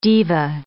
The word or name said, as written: diva